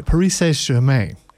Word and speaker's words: Paris Saint-Germain: I fully expect a Paris Saint-Germain from you. Can I get like a Paris Saint-Germain? Can I get? – I don't know if you're pronouncing it right. Paris Saint-Germain.